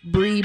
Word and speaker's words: Brie: And you can find me on Instagram and Twitter, Brie Brooks, and it's B-R-I-E, Brooks, and then an underscore.